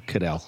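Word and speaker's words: Caddell: I always mispronounce Pat's last name, but Pat Cadell, the pollster. – Yeah, Cadell.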